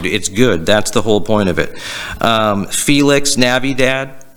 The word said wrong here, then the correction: Feliz Navidad: Felix? – Felix Navidad. – Yeah, it's great. – What? – Feliz Navidad? Or Lavida? – What, am I saying it wrong? – Yeah, I think so. – Yeah, I know. – At least I got the first part. – Feliz Navidad. – There you go.